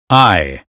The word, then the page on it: eye: American English